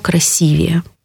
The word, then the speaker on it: красивее: И самое знаменитое из этого перечня, из этой категории слов, это слово «красивее». Здесь нужно запомнить, что ударение будет как в слове «красиво», так и в слове «красивый», «красиво», «красивее». То есть ударение сохранится.